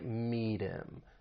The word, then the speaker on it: meet him: Now, the final T in meet, we can use a flap T, and then link that soft D sound with the vowel sound in him. So it's going to sound like meet him, meet him.